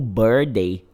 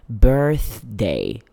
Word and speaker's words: birthday: Cuidado pra não pronunciar ele errado, viu? Eu vejo muitas pessoas pronunciando ele como birthday. Birthday. Ele tem um TH no meio e precisa ser oralizado, ó. Birthday.